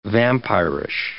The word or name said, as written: vampirish